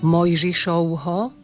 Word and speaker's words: Mojžišovho: Mojžišovho, tu máme v strede slova V a prečítame ho ako U.